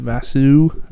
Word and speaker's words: Basu: And today we have Shanti, Basu.